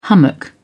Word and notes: hummock: British English

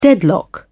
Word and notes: deadlock: British English

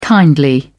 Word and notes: kindly: British English

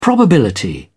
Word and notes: probability: British English

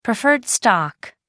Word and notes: preferred stock: American English

